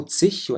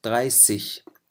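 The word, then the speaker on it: dreißig: ال30 كمان حالة خاصة تنتهي بالاس الحادة بعدين إيجا، مو تسيش وإنما تغاي سيش. إذا اللفظ شوي كمان بيختلف، هون تلفظ مثل الاس وليس مثل الزد، فبنقول تغاي سيش.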